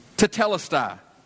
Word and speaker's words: tetelestai: Tetelestai.